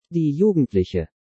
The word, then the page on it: Die Jugendliche: German